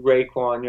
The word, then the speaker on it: Raekwon: Raekwon Williams, which you're not pronouncing the A-E, Raekwon. You're not doing it right.